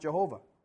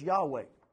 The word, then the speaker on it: Yahweh: that it was never pronounced Jehovah. Just like most scholars will say that it's not pronounced Jehovah. Well, what would happen if they're right and it is Yahweh and not Jehovah?